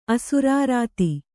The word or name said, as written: ಅಸುರಾರಾತಿ